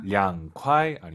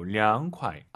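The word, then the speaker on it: 凉快: 량콰이 아니고 량콰이. 아하. 이 발음 기억하세요.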